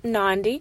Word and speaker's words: Nandi: I am your host, Life Coach Nandi, rhymes with Gandhi.